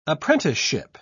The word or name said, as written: apprenticeship